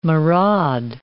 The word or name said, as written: maraud